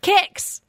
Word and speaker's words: Kyxz: And I'm not sure if I said your first name correctly, because it's spelled K-Y-X-Z, which is so cool, but I don't know how to say it. – Kicks.